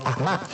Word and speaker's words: 阿嬷: 在票房一路走高的同时，《给阿嬷的情书》的口碑评分也持续领跑档期。在这儿要做一个小小的解释啊，我们在这儿说了阿嬷，这是普通话这个字的读音，但是电影里呢，大家会听到潮汕话说的是类似于阿嬷，所以后面大家听到的很多采访当中，大家也是用了这个读音。